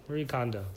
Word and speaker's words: Marie Kondo: It was actually the first episode I watched, but Marie Kondo. I don't even know. That's how you say it, right? Marie Kondo.